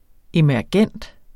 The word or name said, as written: emergent